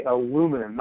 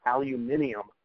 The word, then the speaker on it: aluminum: So you have to say aluminum, not aluminium, I insist.